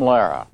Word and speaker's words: Lehrer: You sort of laryned that uh, that pronunciation. It's the way he pronounces his own last name. – Good evening. I'm Jim Lara.